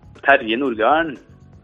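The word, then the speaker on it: Terje Nordgarden: In Norvegia come l'avresti detto? – Tarie Nordgarden. – Ok, perfetto.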